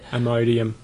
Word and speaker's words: Imodium: Imodium.